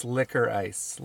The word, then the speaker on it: licorice: It's liquor ice, licorice.